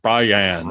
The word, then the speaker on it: Brian: Just take the nickname version, Bri, B-R-I, and just add an A-N at the end of it.